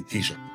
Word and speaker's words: Taoiseach: But uh, generally um, accepted pronunciation would be T-shirt. So T S H O C K.